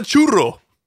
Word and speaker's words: churro: Then it's not a churro. – I love that we're really sticking with this churro thing. – Churro. Roll your R's.